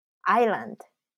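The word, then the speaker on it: island: アイル、まあ、アイランド。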